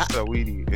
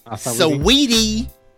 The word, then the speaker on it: Saweetie: Um, Sweetie is that how you say your name? – It's I, Saweetie. – It's like Saweetie. – Saweetie.